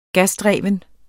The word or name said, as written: gasdreven